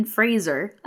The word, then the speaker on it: Fraser: And he ends up getting Brendan Fraser. I didn't know that that's how you're supposed to pronounce his name, but they were very clear about that in this movie.